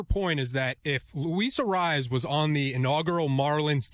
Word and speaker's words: Arraez: Arraez. – Yeah. I mean, Jesus Christ. But Luis, how do you say it Arraez. – Again? – Arraez.